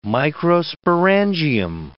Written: microsporangium

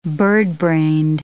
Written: birdbrained

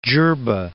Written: Djerba

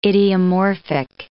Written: idiomorphic

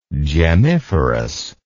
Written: gemmiferous

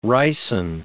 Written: ricin

